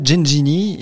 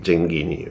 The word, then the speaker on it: Genghini: On l'a toujours appelé Gengini, mais pour commencer, on a appris qu'il fallait bien prononcer Bernard Gengini est l'invité de mon stade à moi. – Genghini, oui, le GH c'est en italien, voilà, c'est Genghini, oui, tout à fait.